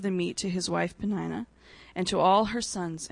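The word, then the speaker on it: Peninnah: We think Sherb and I decided that's how we pronounce her name, so Penina it is.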